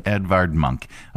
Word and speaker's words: Edvard Munch: Edvard Munch.